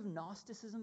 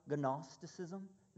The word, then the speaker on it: Gnosticism: Have you heard of Gnosticism before? It starts with a silent G, Gnosticism.